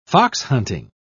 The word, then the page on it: foxhunting: British English